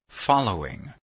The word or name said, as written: following